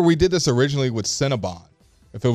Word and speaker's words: Cinnabon: But it's spelled Bon, but it's Cinnabon.